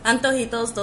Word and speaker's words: antojitos: Antojitos? – Antojitos, those are yes, those are the, the um, food options.